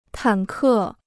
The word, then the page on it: 坦克: tǎn kè